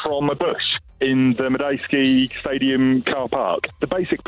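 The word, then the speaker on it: Madejski: So, let's head over to a bush just outside the Majewski Stadium in Reading. And our man in the bush, on the ground. Hello. – Hello there. That was an immaculate pronunciation of Majewski.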